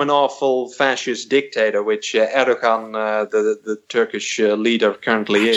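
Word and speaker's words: Erdoğan: It's even Erdogan. I just wanted to point out that it's Obi-Wan. It's Erdogan. People told me about that. So the G, is silent.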